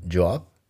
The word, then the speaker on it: Yoav: Así es que te dejo con Job, Así se escribe Y-O-A-V, Job Y la canción Dopamine.